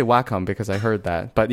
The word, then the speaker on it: Wacom: I read somewhere that, yeah, there is some official source that says, yes, it's Wacom, but I think the companies, like, you know, they're like, we don't really care, just buy our products, but yeah, we say Wacom, so there it is.